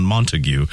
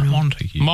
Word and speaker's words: Montague: People said I had a very Shakespearean pronunciation of the town Montague. – Oh, well, is it not? Oh. That's not how it's called. That's how it's spelled. – Hmm. – Montague County. – Yeah. – Montague.